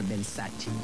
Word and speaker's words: Versace: Por ejemplo, Versace, cuando la marca Versace es italiana, pues se tiene que pronunciar Versace.